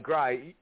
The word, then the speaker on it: Gry: Gry. Yeah, he actually go by Gry, but I always just pronounce it G-R-Y.